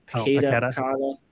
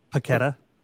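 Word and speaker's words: Paquetá: Paqueta. – What is it? – Yep, Paqueta.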